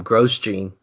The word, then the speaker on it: Grosjean: Kirby, you did mention Mr. Grosjean, or as you pronounce him, Grosjean.